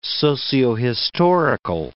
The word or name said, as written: sociohistorical